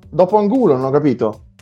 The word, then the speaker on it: Angulo: Farinos, Mendieta, Chili Gonzalez, Gerard, Gerard, non so come si pronunci, Miguel Angel Angulo Scusate la...